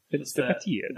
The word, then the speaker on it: Vänsterpartiet: I'm not going to try to say Vänsterpartiet.